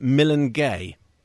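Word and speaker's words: Milange: And Jasjit Singh from the University of Leeds is pointing out that Melange, which we were talking about before, he said your French pronunciation of that isn't really strictly correct, given that Melange means we'll meet again in Punjabi and Hindi and is kind of pronounced Milangay.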